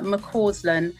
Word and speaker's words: McCausland: You can find me on my website or on Twitter or on Instagram, and it's all my name, which is Fiona McCausland,